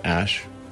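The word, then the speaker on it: Æ: And then the A-E is like pronounced Ash.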